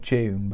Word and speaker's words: DVNE: So that's just me and it's my mate Dan who plays in, have you seen that band called June, but it's spelled like DVNE? – No.